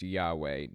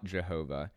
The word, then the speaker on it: Yahweh: The name is pronounced Yahweh, not Jehovah.